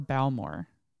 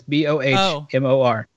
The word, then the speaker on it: Bowmore: Bowmore or Balmore, I think. – Balmore. – Balmore, okay. – Bowmore. – Okay. – The pronunciation guide is Bohmor.